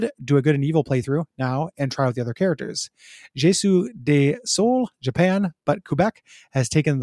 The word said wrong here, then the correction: Je suis désolé: I'm sure I mispronounced that. – Je suis désolé.